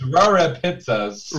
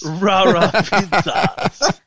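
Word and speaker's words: Rare Pizzas: No, actually, it's Rara Pizzas. – Rara Pizzas.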